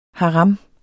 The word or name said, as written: HARAM